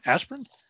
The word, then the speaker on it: Asprin: Asprin? – Yes.